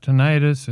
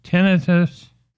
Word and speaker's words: tinnitus: I was saying tinnitus, but my favorite rocker was asked how to pronounce it. It's not tinnitus, it is tinnitus.